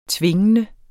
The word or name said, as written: tvingende